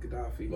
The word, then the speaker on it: Gaddafi: Gaddafi? – Muammar Gaddafi, yeah.